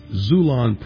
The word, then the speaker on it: Xulon: Today, pick up your copy at Amazon, Barnes & Noble, or with my publisher, Xulon Press. It's pronounced X-U-L-O-N.